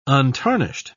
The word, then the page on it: untarnished: British English